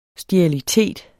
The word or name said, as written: sterilitet